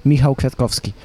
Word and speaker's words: Michał Kwiatkowski: Right, you're going to have to tell me how you pronounce this properly in Polish, but Michał Kwiatkowski? – Close, close, very close. Michał Kwiatkowski.